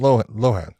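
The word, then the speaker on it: Loughnane: I want to say Lohan, but every time it's Lonane. – Yeah, but so, I think he pronounces it Lohan. – Does he? Okay. – Yeah, I believe so.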